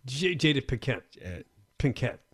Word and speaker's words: Jada Pinkett: Jada Pinkett.